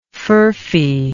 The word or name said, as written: furphy